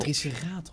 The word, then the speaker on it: triceratops: hoe zeg ik jij Ik vind dat triceratops. triceratops. En dat komt omdat de klemtoon in het Nederlands op de tweede lettergreep van achteren valt in het algemeen.